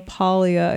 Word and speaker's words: Paglia: I met Camille Paglia. I have to remember to pronounce it with the soft G at a book signing years ago with my sister.